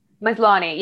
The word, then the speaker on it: Maslany: Maslany.